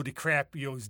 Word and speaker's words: DiCaprio's: What he does is he kills Leo DiCaprio's dad. Did I pronounce that wrong? – No, you said it right.